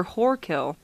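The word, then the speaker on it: Whorekill: Yeah, it's spelled W-H-O-R-E. – Wait, you say poor kill or whore kill? – Whore kill.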